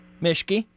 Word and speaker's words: Mischke: Your name is Tom Mischke.